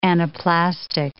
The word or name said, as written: anaplastic